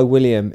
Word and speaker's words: William: I go by William. It sounds so different when Americans say it.